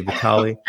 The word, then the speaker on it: Vitaly: Vitaly, depending on how you pronounce his name, I say Vitaly, and Vladimir Klitschko, brothers.